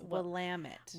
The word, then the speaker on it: Willamette: It looks like Willamette Valley to me. And it's Willamette.